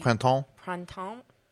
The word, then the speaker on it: Printemps: How do you say that department store? PRIT. – Printemps.